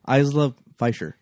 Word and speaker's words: Isla Fisher: Isla Fisher.